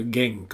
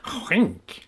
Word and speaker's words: Genk: Alltså man sitter här som en vanlig plebej och säger Genk. Och sen ser man på tv och säger han är nu klar för skänk.